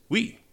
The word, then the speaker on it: oui: It is pronounced oui. It is no longer your debt and my income. It is oui.